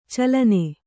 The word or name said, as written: ಚಲ್ಲೆಣ್ಣೆ